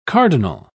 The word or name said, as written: cardinal